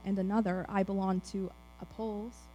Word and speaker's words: Apollos: Apollos, thank you.